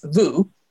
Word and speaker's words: vous: And the formal version is vous, V-O-U-S. Not however RJ pronounced it.